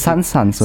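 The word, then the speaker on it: Sun's Sons: es wurde auch schon sehr oft verschieden angekündigt und unterschiedlich ausgesprochen. Sun Suns und so. – Aber, aber es heißt Sun Suns, oder? Sun Suns, okay.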